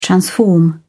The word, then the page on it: transform: British English